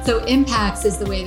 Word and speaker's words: Impax: IMPAX? Is that how you guys pronounce it? – IMPAX, Impact Asset Management, which in the US, you know us as PAX World Funds.